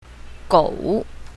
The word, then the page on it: gou3: mandarin